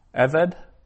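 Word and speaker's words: Eved (עֶבֶד): Now, notice that means servant, and it's got two sigils, so two short e, sounds eved, and that class of nouns, the accent's always on the first syllable. Eved, whereas usually the accent's on the last syllable, but there it's Eved.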